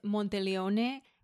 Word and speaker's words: Monteleone: It's an Italian word. Correct pronunciation is Monteleone, which I did not want to repeat like 100 times today. So I'm going to say Hotel Monteleone. I did call the hotel, by the way, just to make sure that was a correct pronunciation and that's how they pronounced it.